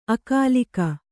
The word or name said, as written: ಅಗಲಿಕೆ